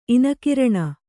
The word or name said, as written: ಇನಕಿರಣ